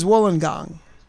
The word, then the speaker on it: Wollongong: Where exactly is, for those who don't have the Australian map in front of them, where is Wollongong? And am I pronouncing it properly? – Yes, that's good. – Very rare that someone from America gets it right the first time.